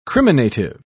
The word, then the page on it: criminative: British English